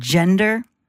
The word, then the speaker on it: gender: If you are going to use the word gender, it's a soft G at the beginning there. Gender. It's not a hard G.